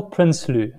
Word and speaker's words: Prinsloo: How do you say it? – Kyle Prinsloo. – Prinsloo. Okay. That's it.